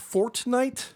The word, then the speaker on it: Fortnite: How is that pronounced? Fortnite? – Yes.